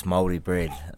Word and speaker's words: Māori: You know, you get bread when it's stale, you call it's Māori bread. So that's how I sort of teach people to say it. It's just M-A-O-R-I, sorry.